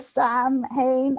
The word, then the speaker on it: Samhain: It's like the reason I really love Halloween 3 is because the actor pronounced Samhain correctly. – Love it. – So like every other one says Samhain as if he's like this guy called Samhain. And it really, really annoys me. And as an Irish person, it was so refreshing to actually have it depicted accurately and, you know, the Irish culture and things like that. So, yeah, it's pronounced Samhain, and he does it very good.